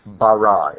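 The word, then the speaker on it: bara (ברא): In Hebrew it's spelled Beit Resh Aleph, bara. In English, B-A-R-A will do, bara.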